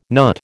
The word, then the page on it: NUT: American English